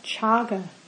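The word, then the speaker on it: cāga: And for this he used a different word, the Pali word chaga. That's spelt C-A-G-A. So in Pali, the letter C is usually pronounced ch, in my, at least in my understanding.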